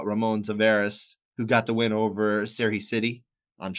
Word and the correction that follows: Sidey: Well, because you would say Spidey, but now just pretend there's no P. So it's Sidey.